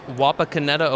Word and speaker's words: Wapakoneta: Yeah, I'm from Wapakoneta, Ohio.